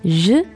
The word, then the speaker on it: je: Je suis. – Mm-hmm. – Okay, so I am. Je, like garage for pronunciation, right? – Yeah, and suis.